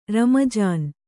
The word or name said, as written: ರಮ್ಮಜಾನು